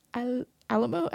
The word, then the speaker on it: Alaimo: But it's Steve Alamo.